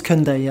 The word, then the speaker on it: Koundé: Jules Koundé, yeah.